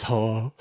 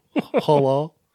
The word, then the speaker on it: Hulu: I believe it's pronounced Hulu. – I believe it's not. It's Hulu. – Hulu? – Hulu. It's a long U. Hulu.